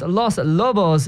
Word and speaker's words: Los Lobos: And well, that was Los Lobos there, as it's actually properly pronounced, with their hit La Bamba, paying tribute to one of this year's hosts of the FIFA World Cup, Mexico, right there.